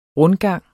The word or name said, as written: rundgang